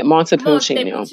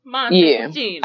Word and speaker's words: Montepulciano: I looked up how to say this word before. Montepulciano. Yeah, Montepulciano. – Montepulciano.